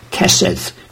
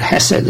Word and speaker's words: hesed: The Hebrew word is the Hebrew word hesed, or kesed, depending how you pronounce it. But hesed is the more near pronunciation.